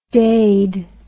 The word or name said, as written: daid